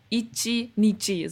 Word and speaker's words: Ichi Nichi: Also Marina no Ichi Nichi, so wird es ausgesprochen.